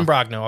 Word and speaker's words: Imbrogno: So, I'm saying his name wrong. Imbrogno? It's I-M-B-R-O-G-N-O. – Imbrogno? I don't know. – Imbrogno. I'll go with Imbrogno and Hynek.